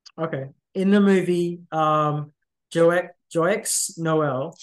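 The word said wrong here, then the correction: Joyeux Noël: Joie Noel. – Joie Noel. See, I'm already being corrected already.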